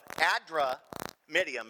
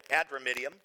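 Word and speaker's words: Adramyttium: And embarking in a ship of Adramidium, is how it's pronounced. Cameron nailed it. Adramidium,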